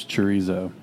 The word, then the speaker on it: chorizo: The secret ingredient was chorizo, which rhymes with pastuzo, so we could just do that.